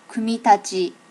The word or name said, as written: kumitachi